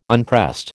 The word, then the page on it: unpressed: American English